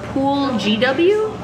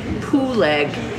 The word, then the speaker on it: Pooleg: Or Pool GW? I'm guessing it's Pool GW. – Not spell it. – But it looks like Pool. – P-O-O-L-E-G. – It looks like Pooleg W. P-O-O-L-E-G-W. – Yeah, that's old Pooleg.